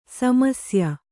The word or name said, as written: ಸಮಸ್ಯೆ